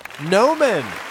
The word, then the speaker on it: Gnomon: Gnomon. – Gnomon. That's right. Now go ahead. – It starts with a G.